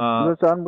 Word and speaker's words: Lausanne: The other one's, in my mind anyway, is the Treaty of Lausanne. And I'm not sure I'm pronouncing mm-hmm. that right, but... – Yeah. Lausanne would do, yes.